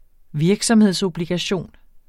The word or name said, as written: virksomhedsobligation